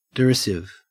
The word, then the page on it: derisive: Australian English